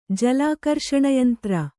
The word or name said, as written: ಜಲಾಕರ್ಷಣಯಂತ್ರ